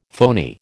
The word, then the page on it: phoney: American English